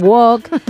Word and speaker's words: walk: Os diría que no pronuncian la L, al igual que ocurre en Walking. – Walking Dead. – En Walk. En Island no pronuncian la S.